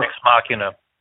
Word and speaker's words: Ex Machina: I did not see, I'll pronounce it wrong, Ex Machina or Inside Out. – Ex Machina.